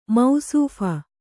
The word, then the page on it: ಮೌಸೂಫ: Kannada